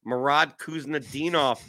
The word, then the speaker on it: Murat Kuznetsov: Apparently, it's Happy Murat Kuznetsov Day. – That's actually well pronounced, by the way. I looked it up phonetically.